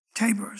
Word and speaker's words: Tabor: And I'm actually going to get three of them in today, but the main one is going to be Mount Tabor is how it's pronounced.